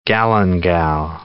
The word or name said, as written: galangal